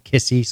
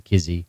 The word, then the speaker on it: Kissy: Some call him Kissy, some call him Kizzy. – I don't know what to call him. – It's spelled K-I-S-S-Y.